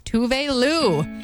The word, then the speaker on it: Tove Lo: Tove Lou, that's Habits, my Mix FM, otherwise known as Tove Lo, but I just heard her pronounce her name for the first time the other day, and it's Tove Lou.